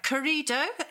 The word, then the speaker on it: Corrido: And it's called, let me get this right, Corrido. Is that how I might pronounce it? – Yeah.